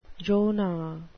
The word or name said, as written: Jonah